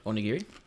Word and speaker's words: onigiri: Nigiri is the sushi. Onigiri is the rice ball.